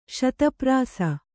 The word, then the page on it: ಶತಪ್ರಾಸ: śata prāsa